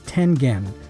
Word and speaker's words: Tengen: The correct pronunciation for the name of the company was Tengen, with a hard G. The name, as I mentioned, is a term derived from the Japanese tile game Go, and in Japanese, with very few exceptions, a G is always pronounced with a G sound, not a J sound.